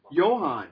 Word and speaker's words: Johann: How do you say Bach's first name? – Johann. – Oh, yeah, but if you look at it, it's Johann. I always thought, how did I get Johann on a Johann? Well, nobody ever told me that the Germans didn't pronounce a J the way we did.